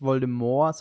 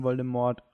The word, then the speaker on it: Voldemort: Ich glaube aber auch, dass es im Film nicht Voldemort, sondern Voldemort ausgesprochen wird.